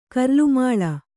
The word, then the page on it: ಕರ್ಲುಮಾಳ: kannada